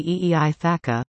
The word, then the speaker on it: Aithka: By the way, he often used his language's word Aithka, pronounced approximately E-E-I Thaka, for Stony Nakota.